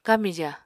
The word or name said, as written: kamija